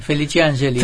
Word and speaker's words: Felici Ángeli: Vicente Felici Ángeli, está bien, ¿no? – Sí, Felici Ángeli. – Felici Ángeli, es como se pronuncia en italiano.